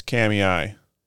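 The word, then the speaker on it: Kamiah: Kamia? – That was about the fucking closest. It's Kamiai.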